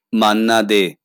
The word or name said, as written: মান্না দে